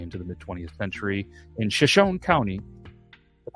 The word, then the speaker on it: Shoshone: Shoshone.